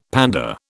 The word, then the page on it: pander: British English